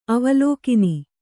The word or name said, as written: ಅವಲೋಕಿನಿ